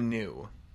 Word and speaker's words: Gnu: What is the new? Is that, am I pronouncing that right? GNU? – GNU. – Yeah, new.